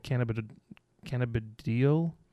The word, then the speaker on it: cannabidiol: Cannabinoids. No, it's not cannabinoids. It's cannabidiol.